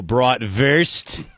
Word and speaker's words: bratwurst: I want to say that you guys are both saying bratwurst wrong. – What? – What do you mean? – The W is pronounced with a V. – So bratwurst.